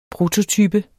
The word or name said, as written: prototype